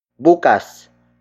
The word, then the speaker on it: bukas: The next word is bukas. Bukas. Bukas. Bukas is spelled as B-U-K-A-S.